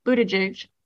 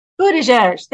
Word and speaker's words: Buttigieg: Pete Buttigieg, whose name I'm probably mispronouncing, of course, is in a very supercharged position because he's Buttigieg. – Thank you.